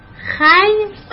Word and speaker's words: خوی: بچه ها خوی نوشته میشه، خی خونده میشه، یعنی عرق کرده.